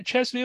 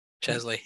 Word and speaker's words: Chesley: And has won a Chesley, is it a Chesley Award? – Chesley, yep. – Chesley, there we go.